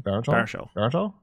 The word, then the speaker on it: Baruchel: Baruchel.